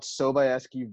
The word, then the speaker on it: Sobieski: and we bought Sobieski vodka.